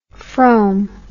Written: Frome

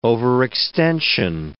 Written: overextension

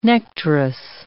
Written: nectarous

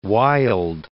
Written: Wyld

